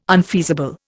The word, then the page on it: unfeasible: Indian